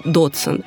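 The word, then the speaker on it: Dodgson: Свою настоящую фамилию математик не очень любил, настаивал, что правильно она произносится Доджсон.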